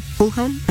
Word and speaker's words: Fulham: Fulham, así se dice.